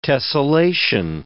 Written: tessellation

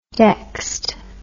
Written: dexed

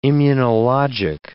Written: immunologic

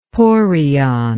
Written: porion

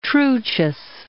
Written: Truchas Peak

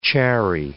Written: chary

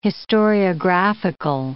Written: historiographical

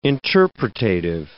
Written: interpretative

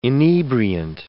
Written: inebriant

